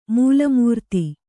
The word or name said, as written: ಮೂಲಮೂರ್ತಿ